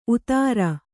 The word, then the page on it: ಉತಾರ: utāra; kannada